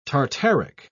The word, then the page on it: tartaric: British English